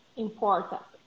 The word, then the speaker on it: important: Important. – Now you said it right. Now you said it right. Agora você disse direitinho. Important. Important. Important. A ênfase está na segunda sílaba ali.